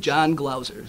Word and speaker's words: Jon Glauser: My name's John Glauser. – Spell it. – John, J-O-N, G-L-A-U-S-E-R.